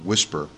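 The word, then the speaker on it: WSPR: Whisper, I think it's weak signal propagation reporting, and it's pronounced Whisper.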